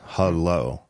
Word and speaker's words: Hullo: Uh, this is Hullo Pillow, pronounced Hello.